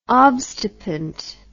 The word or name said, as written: obstipant